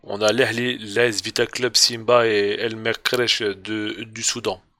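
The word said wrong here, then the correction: Merrikh: Mérir, pardon, je l'ai mal prononcé.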